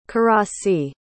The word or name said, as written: karasi